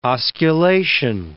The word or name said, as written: osculation